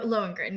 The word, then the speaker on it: Lohengrin: Yeah, there were moments where, so, yeah, I mean, the Lohengrin, am I saying that right? – Lohengrin, yes.